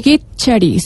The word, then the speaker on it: kitchari: Kitchari, se escribe con K al comienzo, kitchari.